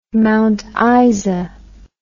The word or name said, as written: Mount Isa